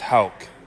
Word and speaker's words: Houck: I get Houck, Hawk. I'm sure I've gotten a few other random ones here and there. But yeah, it's most of the time I would say it's pronounced wrong.